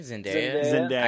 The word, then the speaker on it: Zendaya: I think Zendaya. I say Zendaya.